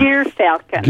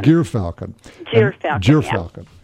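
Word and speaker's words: gyrfalcon: Is it a GYR Falcon. – Gear Falcon. – Gear Falcon.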